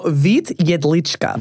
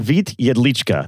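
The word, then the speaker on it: Vít Jedlička: Is a man whose name I might not pronounce very well called Vit Jedlička. – So Vit Jedlička. – Vit Jedlička. There we go. See? – Good. That was really good.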